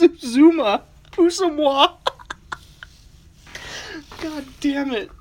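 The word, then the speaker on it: Uzomah: I didn't even pronounce that correctly at all. Uzuma. I was like, Usama.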